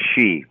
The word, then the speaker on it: Xi: And that's it's pronounced Xi, believe it or not.